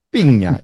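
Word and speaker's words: Pina: Piña. Le digo Piña, pero se escribe sin ñ.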